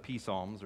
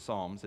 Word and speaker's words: Psalms: And so he's before the P Psalms, or the Psalms, if you do not pronounce the P like most adults do.